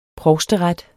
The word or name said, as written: provsteret